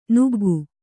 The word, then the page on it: ನುಗ್ಗು: nuggu